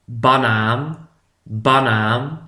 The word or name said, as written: de banaan